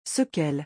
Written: sequelles

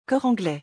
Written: cor anglais

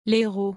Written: les heros

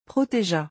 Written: protegea

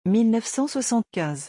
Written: 1975

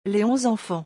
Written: les onze enfants

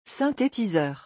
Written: synthetiseur

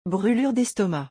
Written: brulures destomac

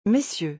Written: messieurs

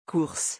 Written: course

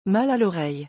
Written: mal a loreille

